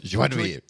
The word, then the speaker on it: joie de vivre: Joie de vivre.